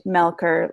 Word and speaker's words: Melcher: look me up, it sounds like Melcher, C H, but it's pronounced Melker, like a K.